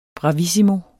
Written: bravissimo

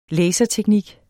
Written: laserteknik

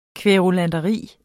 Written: kværulanteri

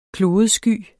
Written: klodesky

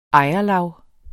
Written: ejerlav

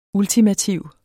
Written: ULTIMATIVE

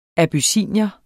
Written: abyssinier

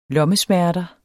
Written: lommesmerter

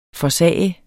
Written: forsager

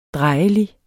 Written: drejelig

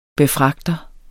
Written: befragter

